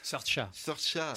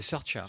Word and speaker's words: Saoirse: Saoirse Sorcha. C'est Sorcha.